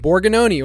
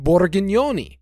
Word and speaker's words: Borgognoni: My last name, it's Italian, Borgognoni, or if you, you know, if you're pronouncing it in a more thick Italian accent, Borgognoni.